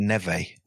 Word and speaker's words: Neve: I would say Nev or Neve. – I think they pronounce it Neve. – Neve. Yeah.